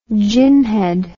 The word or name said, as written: ginhead